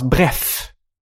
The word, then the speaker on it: Bref: Så var det enda de hade att säga, det var att varumärket uttals bref. Du säger bref, WC. Och de kanske bara vill att jag ska säga bref och inte bref, WC.